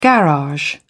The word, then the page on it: garage: British English